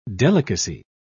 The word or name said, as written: delicacy